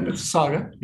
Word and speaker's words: Mississauga: It's a happy birthday for Tony Visco, 58 years young today, the pride of Mississauga. I'm messing up. Sorry, I'm going to let you pronounce it. You got it, Mississauga.